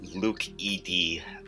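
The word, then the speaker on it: LukED: So I think it's pronounced Leucad. And the reason is that E and D are subunits. So they're coded by two different genes.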